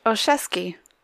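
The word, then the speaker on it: Olszewski: her last name is pronounced Osheski. In case anybody wanted to know how to pronounce that.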